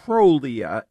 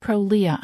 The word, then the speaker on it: Prolia: Or is it pronounced Prolia? I'm never sure exactly how, and I've heard it both ways. – I've heard heard it in both scenarios as well. You could probably use them interchangeably.